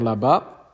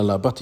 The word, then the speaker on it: Labat: Die Distillerie Poisson ist besser bekannt für den Rum Père Labat. Oder Labatt, ich weiß gar nicht, ich glaube, das T spricht man nicht mit, ist ja auch egal.